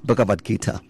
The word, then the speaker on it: Bhagavad Gita: Buddhists, they believe in the Bhagavad Gita. I don't know how I can pronounce it. Yes, Bhagavad Gita, correct.